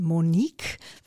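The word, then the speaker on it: Moneyque: Und äh, die neue Firmenseite heißt Monique, wird aber geschrieben Money, Q-U-E.de.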